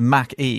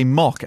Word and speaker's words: Mach-E: Much crap on YouTube and elsewhere for calling it the Mach-E, because that's how I say Mac, like Concord and all the rest of it was Mac. Something. – How do you say McDonald's? – McDonald's. – Okay. – And apparently I'm supposed to say mock, as in like coffee. – Mock.